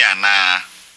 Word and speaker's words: ana: Wo ye America ni ana. – Notice the last word in line 9. A double A is pronounced longer than a single A.